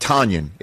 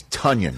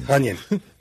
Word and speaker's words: Tonyan: Being called Tonyan. It's Tonyan. – Tonyan.